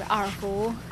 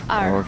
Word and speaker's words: erhu: So it's spelled E-R-H-U, arhu. – Okay. – It's two distinct words. In Chinese character, actually, it's R and Hu.